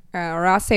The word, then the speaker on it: Rothesay: A lot of people will read it and they'll pronounce it as Rosse, but that's not right, obviously.